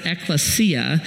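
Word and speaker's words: ecclesia (ἐκκλησία): The word church is actually a Greek word, and you, in Greek you pronounce it ecclesia, and it means called out ones.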